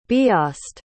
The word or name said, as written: biast